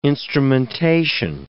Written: instrumentation

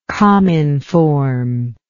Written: cominform

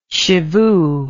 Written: shivoo

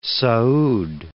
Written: Saud

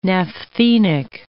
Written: naphthenic

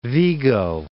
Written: Vigo

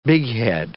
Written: bighead

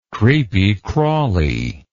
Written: creepy crawly

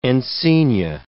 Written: encaenia

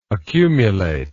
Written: accumulable

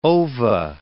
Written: ova

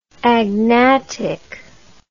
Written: agnatically

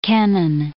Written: Kennan

